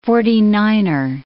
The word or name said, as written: forty-niner